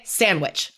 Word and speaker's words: sandwich: So instead of saying sandwich, we say sandwich. Like that D is gone into the ether.